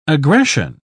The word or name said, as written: aggression